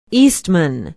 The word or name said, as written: eastman